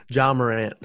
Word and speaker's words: Ja Morant: And I always pronounce this guy's name wrong. Jay Morant, right? – Ja Morant. – There you go.